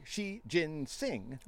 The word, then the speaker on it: Xi Jinping: Meanwhile, Chinese President Xi Jinping, or I saw that, said that wrong, Xi Jinping, told Trump that China will work with the U.S.